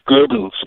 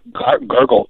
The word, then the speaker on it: Goebbels: And Joseph Goebbels, actually, his name should be pronounced Goebbels, not Gurgle.